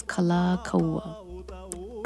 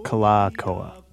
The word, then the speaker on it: Kalākaua: Is it Kalākaua? – So it's Kalākaua. – Kalākaua.